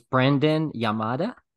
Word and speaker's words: Branden Yamada: Wow, guys, this was Branden Yamada.